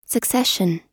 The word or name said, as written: succession